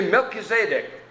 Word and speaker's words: Melchizedek: We were toying with different ways of Melchizedek. No. Well, anyway, I said, you should just say Melchizedek. And pretty soon they'll think, I've been saying it wrong all these times. – It's true. – Yeah, that's more the Hebrew pronunciation of it.